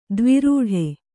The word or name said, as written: ದ್ವಿರೂಢೆ